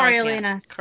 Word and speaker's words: Coriolanus: So, speaking of Tom Hiddleston in current productions, he is currently starring in somebody pronounce it properly because I know Coriolanus.